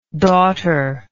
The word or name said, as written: daughterlike